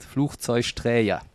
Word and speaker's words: Flugzeugträger: Aber das erste spricht sich wie CH, Fluch, dann Zeusch, weil wir in Köln gerne Sch-Laute machen. Und Träger, weil auch vieles mit so einem J belegt wird. Flugzeugträger.